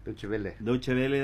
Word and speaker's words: Deutsche Welle: Deutsche Welle de Alemania, así se pronuncia.